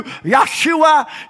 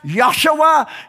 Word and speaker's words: Yahshua: Yes, it's pronounced different in Hebrew, Yahshua. Yes, it's pronounced Yahshua.